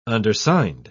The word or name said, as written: undersigned